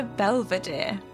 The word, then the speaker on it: Belvedere: In this episode, we're talking about chapter 11 of The Subtle Knife, The Belvedere.